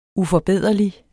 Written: uforbederlig

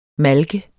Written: malke

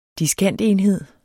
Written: diskantenhed

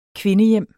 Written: kvindehjem

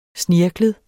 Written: snirklet

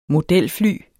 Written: modelfly

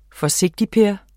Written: forsigtigper

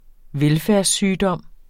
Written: velfærdssygdom

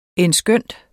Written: endskønt

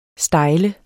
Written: stejl

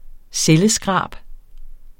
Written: celleskrab

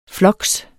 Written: floks

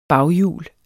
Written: baghjul

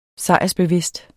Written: sejrsbevidst

